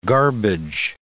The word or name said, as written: garbage